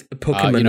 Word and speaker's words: Pokimane: So, you know, woke up to find out that Twitch streamer and I guess an internet personality, Pokimane is the most I've actually said her name out loud, so I have to make sure I'm like saying it correctly. – No, it's Pokimane.